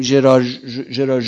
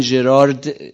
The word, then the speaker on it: ژرار: مثلا ژرار ژونت رو نوشتن ژرارد ژونه که دیگه متخصصش اینجا نشسته، من هیچی نمیتونم بگم، غلطه دیگه، درسته؟ یعنی هم ژراردش غلطه هم ژونش غلطه. این ژرارد نیست، دی آخرش تلفظ نمیشه، میشه ژرار، اونم میشه ژونت.